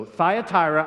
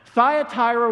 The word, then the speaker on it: Thyatira: As a matter of fact, I Googled it just to make sure I was pronouncing it right. You know, so Thyatira, I was putting an extra R in there all week. So Thyatira was one of the smallest cities in the Asia Minor province However, they received the longest letter from Jesus.